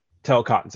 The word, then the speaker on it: Talokans: how do you say it, Tekolians? Talokans, there you go.